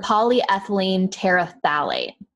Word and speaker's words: polyethylene terephthalate: I just want to make sure I say it right. – Yeah, for sure. – Polyethylene terephthalate. That's it.